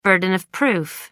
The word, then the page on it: burden of proof: British English